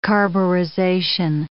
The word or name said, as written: carburization